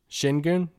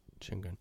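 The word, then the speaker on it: Şengün: But it's, like, is there a world that you take Sengun? Did I say it right? – I believe the S is like a sh, but it's the, there's an umlaut over the U.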